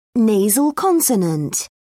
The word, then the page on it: nasal consonant: British English